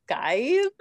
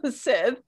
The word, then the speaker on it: scythe: It's like Skythe or is it Sith? – I think it's Sith. – Sith, yeah.